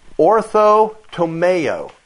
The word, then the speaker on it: orthotomeo: This phrase, rightly dividing, comes from the Greek word orthotomeo. I'll spell it for you. O-R-T-H-O-T-O-M-E-O.